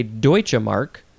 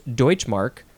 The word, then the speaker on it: Deutschmark: It's got a capital D hyphen mark, and you could also say Deutsche Mark. Oh, sorry, it's actually pronounced Deutschmark, but it's spelled demark, and it is a noun from 1948, and the synonym is Deutschmark.